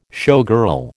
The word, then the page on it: showgirl: American English